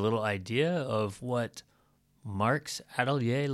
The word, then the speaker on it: atelier: we walked over to Marc's atelier in Seoul's Fine Arts District in Gangnam. Hope I'm saying that right. – Atelier.